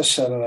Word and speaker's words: subtle: Actually, this is how subtle I am. I'm subtle like the silent B in the word subtle.